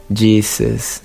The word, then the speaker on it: Jesus: Pues la diferencia es que eh, en español nosotros pronunciamos otra vez la segunda vocal como una A o la segunda sílaba, perdón, como una A. Jesus. Incluso abrimos nuestra boca para pronunciar la A. Jesus. Pero la pronunciación correcta no tiene una A, entonces suena como Jesus.